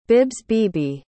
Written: bibsbebe

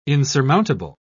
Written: insurmountable

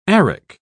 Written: arrack

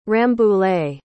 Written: Rambouillet

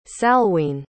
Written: salween

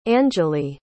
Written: angelie

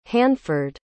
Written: hanford

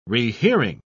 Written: rehearing

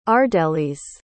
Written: ardelis

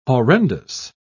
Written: horrendous

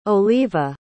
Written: Oliva

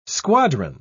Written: squadron

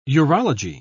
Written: urology

